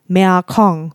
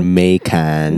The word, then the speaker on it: Mekong: Mekong. That's how you pronounce it. Mekong. – Mekong. – Mekong. Khong. – Not so, Mekan. – Not Mekong. No, it's Mekong. Or something like that.